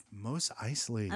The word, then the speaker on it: Mos Eisley: Mos Eisley.